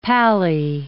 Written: pally

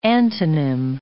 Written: antonym

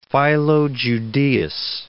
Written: Philo Judaeus

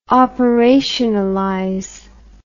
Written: operationalize

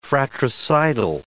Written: fratricidal

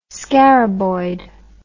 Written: scaraboid

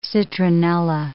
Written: citronella

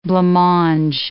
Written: blancmange

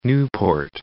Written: Newport

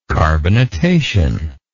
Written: carbonatation

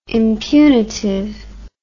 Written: impunitive

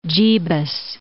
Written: Jebus

